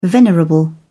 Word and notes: venerable: British English

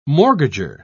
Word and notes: mortgager: British English

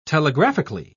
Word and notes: telegraphically: British English

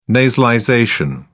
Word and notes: nasalization: British English

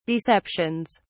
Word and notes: deceptions: British English

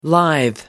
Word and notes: lithe: American English

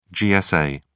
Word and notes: gsa: British English

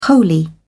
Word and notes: wholly: British English

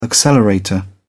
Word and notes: accelerator: British English

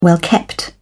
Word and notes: well-kept: British English

